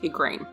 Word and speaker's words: Igraine: Her name is sometimes pronounced Igraine. – Right. Here it's Eugairn, spelled with a U and everything. – Wow. – But yeah, better known as Igraine.